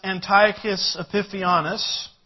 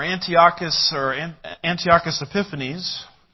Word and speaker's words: Antiochus Epiphanes: This is exactly what Antiochus Epiphanes, or Antiochus Epiphanes, whichever syllable gets the emphasis, as I like to say.